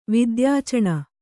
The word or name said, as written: ವಿದ್ಯಾಚಣ